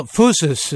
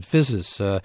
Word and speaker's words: phusis: Well, phusis, excuse me, because I want to. It's like hubris. The Y is pronounced like an O. – Exactly. – But I think about it as etymologically related to physics, so I've said physis.